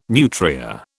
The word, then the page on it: nutria: British English